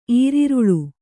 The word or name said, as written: ಈರರಿಲ್